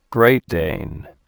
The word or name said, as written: Great Dane